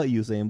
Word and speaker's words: Usain: It is a Usain Bolt. Even though it's spelled with a U, it's a... It's Usain. Is it Usain or Usain?